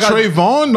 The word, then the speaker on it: Trayvon: Was it Trayvon? – I really forgot the name. I know where you're going. – Did I say his name wrong though? – The dude Trayvon? No, that's his name.